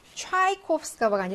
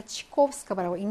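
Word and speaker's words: 치코프스카바라고 (Чайковского): 강세가 없으니까 차이코프스카바가 아니라 치코프스카바라고 읽는 겁니다.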